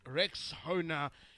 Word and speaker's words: Rexona: I'm sure you've seen that Shield is now Rexona, but it appears some people don't know how to pronounce this new name. A friend of mine even called it Rexona. Guys, it is Rexona, like Rexona, Rexona.